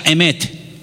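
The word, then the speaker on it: emet (אמת): Y la palabra verdad en hebreo se pronuncia emet.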